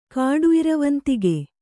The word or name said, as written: ಕಾಡುಇರವಂತಿಗೆ